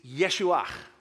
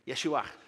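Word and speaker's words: Yeshuach (ישוע): The Hebrew name is Yeshuach, and I won't make you pronounce it, right? Yeshuach.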